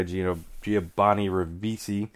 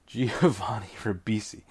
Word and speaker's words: Giovanni Ribisi: Giovanni Ribisi. I butchered that, I think. Giovanni Ribisi.